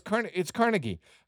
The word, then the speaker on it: Carnegie: Did y'all know Andrew Carnegie? That is not how you say his name. I was like, no, it's like, no. It's Carnegie. Apparently, it's Scottish. It's Carnegie. I did not know that.